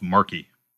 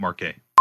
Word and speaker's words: Markey: We sat down with Eileen Marquet to talk about the book, and it's pronounced Markey, not Marquet, about Wayne, about Donald Trump, and you know, the state of American journalism now.